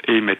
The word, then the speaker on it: Eymet: Mais je crois qu'il prononce Aimé. Faudrait le demander à la madame de l'atelier d'épicure, là, parce que... Je pense qu'il prononce, c'est E-Y-M-E-T, donc c'est une bastide.